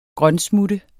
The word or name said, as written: grønsmutte